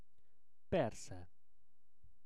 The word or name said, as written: persze